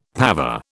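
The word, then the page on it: pavé: British English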